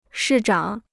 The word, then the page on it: 市长: shì zhǎng